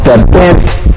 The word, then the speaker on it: Bengt: I did grow up here in Utah with Bent as my real name. That's not a comedy name. It's a Swedish. Any other Swedish names? Okay. Swedish is spelled B-E-N-G-T in Sweden. You would say, hey, what happened?